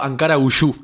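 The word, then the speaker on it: Ankaragücü: primero Ankara Uyú es la pronunciación, que quiere decir la fuerza de Ankara.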